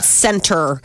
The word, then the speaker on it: Centr: It's called Center. It's spelled C-E-N-T-R.